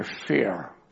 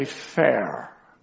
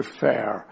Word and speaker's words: Fair: And he was just silent a moment, and he said, yes, her name is Sister Fear. He said, we would say Fair. She's a young German woman. Her name is Sister Fair.